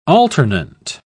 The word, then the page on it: alternant: British English